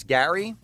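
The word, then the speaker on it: Gerry: His name is, it's spelled like Jerry, but it's Gary.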